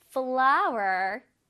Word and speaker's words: flour: 자, number two는 flower. Flower. 밀가루입니다. 자, 이게 얘들아 꽃 flower랑 발음이 비슷해요. 똑같아요, 사실. 근데 스펠링이 다르지. 꽃은 F-L-O-W-E-R 이면은 얘는 F-L-O-U-R 입니다. 근데 발음은 똑같아.